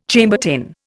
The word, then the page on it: Chambertin: Indian